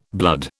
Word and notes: blood: British English

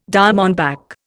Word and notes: diamondback: Indian

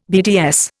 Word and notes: BDS: Indian

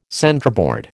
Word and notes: centreboard: American English

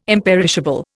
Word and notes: imperishable: Indian

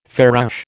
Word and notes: farouche: American English

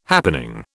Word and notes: happening: British English

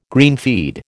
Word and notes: greenfeed: American English

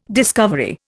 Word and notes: discovery: Indian

